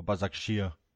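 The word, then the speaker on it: Başakşehir: And then uh, the Turkish side Basaksehir or Bazakshir, um, as I was told um, by one of our Turkish taxi drivers on the weekend. That's how you say it, apparently.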